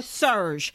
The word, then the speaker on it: SURJ: But one organization we think you could check out on Instagram, especially, is Surge. And it's spelled S-U-R-J.